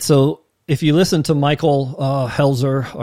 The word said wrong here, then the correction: Heiser: Someone told me there's no L. Heiser, Michael Heiser, thank you.